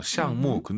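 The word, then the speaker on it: 项目: 프로젝트 발음 둘다 사성이에요. 시앙무. 근데 원어민 선생님들 발음 들어보시면 무를 약간 경성으로 발음해서 시앙무라고 많이 하더라고요. – 是的，口语里常常是这样说。 – 일상생활에서는 구어체에서는 경성으로도 하니까 여러분들이 시앙무 하는 것보다 시앙무 이것도 괜찮을 것 같다.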